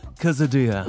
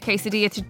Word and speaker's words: quesadilla: Is it a quesadilla? – Quesadilla. Or as Nigella says, a quesadilla. – Well, I'm going to say it like Nigella, quesadilla.